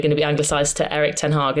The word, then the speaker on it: Erik ten Hag: Yeah, so in Dutch, we say Erik ten Hag.